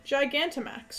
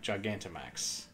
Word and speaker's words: Gigantamax: Oh, Gigantmax? Fine. But that's a chonky Pikachu. – Big chonky boy. – It's like OG Pikachu. – Yeah, Gigantamax. – Sorry, Gigantamax. That's too many syllables.